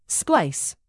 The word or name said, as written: splice